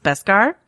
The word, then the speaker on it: Beskar: So I want to talk about, I believe it's pronounced Beskar, right? Which mm-hmm.